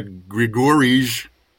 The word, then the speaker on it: Grigorij: We got Grigorij. I think I'm pronouncing that incorrectly, but it's looks like Grigorij, I-J-E. – The J is a Y, so it's like Grigori.